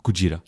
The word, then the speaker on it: Gojira: Eller i Japan då, Gojira.